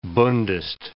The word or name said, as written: bundist